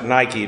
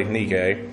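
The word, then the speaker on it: Nike (νίκη): And nikao is the verb, N-I-K-A-O. Nike, means to be a victor, and nikao means to win or be victorious. Although we pronounce it Nike today, but it's really Nike.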